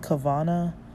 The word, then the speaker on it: Kavanah: And then so there's a topic that I came across because I know about this topic already, but it's called Kavana, K-A-V-A-N-A-H, or it seems like there's other spellings, but with the, or, you know, Kavanot or Kavanos.